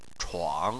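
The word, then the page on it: chuang3: tone 3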